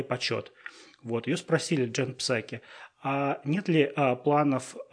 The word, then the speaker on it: Псаки: Вот, это все очень э, интересно и завуалированно подавалось сегодня на а, брифинге а, в Белом доме в исполнении Джен Псаки. А вы знаете, что, между прочим, Джен Псаки, фамилия, фамилия, первая буква «П» не читается,